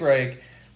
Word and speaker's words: Reich: Reich.